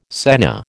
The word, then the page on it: senna: American English